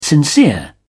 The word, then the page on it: sincere: British English